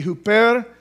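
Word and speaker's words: huper (ὑπέρ): We would say it in the Greek language, it would be huper, but it's the word hyper. In English, it would pronounce hyper.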